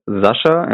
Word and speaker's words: Sascha: So my first name is Sascha, and then the last name is Edelkraut.